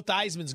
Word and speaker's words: Theismann: Or, as he told us a year ago and confirmed a story I had been chasing forever, Dibs, it is in fact pronounced Theismann. – I don't know if I can make that change. I mean, I've been a fan for 30-something years. I'm not ready to make that switch. But if he says Theismann, I'll try my best. – The problem was that Theismann didn't rhyme with Heisman.